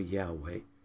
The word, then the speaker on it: Yahweh: It is pronounced Yahweh, more accurately, some pronounce it Jehovah.